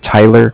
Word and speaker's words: Tyler: My name is, again, Tyler, T-Y-L-E-R, and I'm calling with Cardiff. It's spelled C-A-R-D-I-F-F, as in Frank Frank.